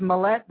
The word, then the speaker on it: Mallett: It's Mallett that rhymes with Gillette, like the shaver.